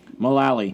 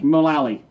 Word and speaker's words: Mullally: Megan Mullally from... – Mullally. – Mullally.